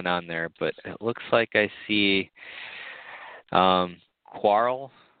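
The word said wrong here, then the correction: Qarl: I think that's how you say it's Carl. – The name. Carl? Okay.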